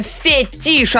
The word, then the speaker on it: фетиш: Неправильно, фетиш, Антон, потому что это слово французского происхождения.